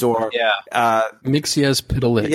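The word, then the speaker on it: Mxyzptlk: And I wasn't sure if you read the Superman Reborn, if he was talking about, and I can never say it right, Mr. Mixpixel or Mixelplix or whatever his name is. – Mixias Pitalix. – Yes, thank you.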